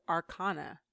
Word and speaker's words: Arcana: And so it's Arcanum, and the plural of that is Arcana, but that's the ah sound. – Ah. – Arcana. – Okay. So it's from I believe it's Arcana.